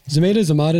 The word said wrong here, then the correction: Zamata: did I say that wrong? – Sashir Zamata. – Why did I say that? That's what I thought. And then I changed it in my mouth.